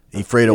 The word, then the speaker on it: Ephrata: Ephrata, yeah.